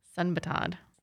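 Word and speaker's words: Sudbanthad: Pichaya Sundbantad. – Got it. – We'll just spell that. It's Sundbantad.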